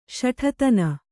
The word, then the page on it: ಶಠತನ: Kannada